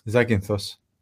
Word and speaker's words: Zakynthos: And when I was on Zakynthos, right? – Zakynthos. – I couldn't pronounce it.